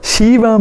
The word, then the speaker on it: Shiva: He said, the Bhagavatam says, consisting of only two syllables, she and va. The sound purifies one of all bad karma. Again, a transformative experience waits for us. Shiva means Mangala, writes my spiritual master, or auspicious.